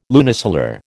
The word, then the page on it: lunisolar: American English